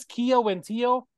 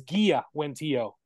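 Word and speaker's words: Kiawentiio: And then you got, it says Kia Wentio, but I hear them when they pronounce her name, it's Gia Wentio, who plays Katara.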